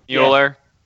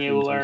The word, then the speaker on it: Mueller: Mueller. – Yeah. He's American, Mueller. So he's not going to be pronounced any more spectacularly.